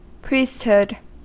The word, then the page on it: priesthood: American English